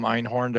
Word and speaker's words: Einhorn: Feel free to reach out to me on LinkedIn, Dom Einhorn, that's D-O-M. Last name is spelled E-I-N-H-O-R-N.